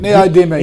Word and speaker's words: Neodymium: Neodymium. – That's it? Neodymium.